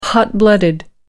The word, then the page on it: hot-blooded: American English